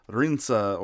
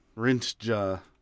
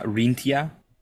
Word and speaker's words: Rinca: The cryptid was first reported on the Indonesian island of Rinca or Rintja. There's two pronunciations here. – Is that Rintia? – No.